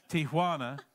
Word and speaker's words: Tijuana: And she is with YWAM, and she is south of San Diego, south of Tijuana, let's say it right, Tijuana, and has been there for several years.